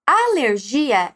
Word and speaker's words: alergia: Alergia, em espanhol, é alergia.